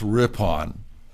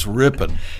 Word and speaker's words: Ripon: And it's not pronounced Ripon. It's Ripon, Wisconsin.